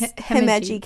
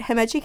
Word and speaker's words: Himeji: So I chose I'm gonna screw up how to say it, but it's Himeji Castle. – Yeah, there you go. He did it. – I'm gonna say it probably five different ways throughout this episode, so bear with me. But Himeji